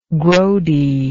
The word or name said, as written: grody